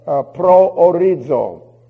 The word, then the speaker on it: proorizo: That's the sum total of the word proorizo. I will spell it for you. P-R-O-O-R-I-Z-Z-O. That's the Zeta, by the way. P-R-O-O-R-I-Z-O.